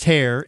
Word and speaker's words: Herr: If you're looking it up online, it's H-E-R-R, pronounced hair, H-E-R-R Construction.